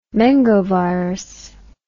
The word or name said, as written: mengovirus